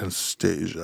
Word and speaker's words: Anastasia: You have to say it like that. Anastasia.